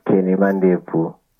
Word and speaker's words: Kenny Mandebvu: The name is Kenny Mandebvu.